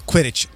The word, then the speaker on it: Quidditch: The Lego Harry Potter Quidditch match. Did I say that right? – Quidditch.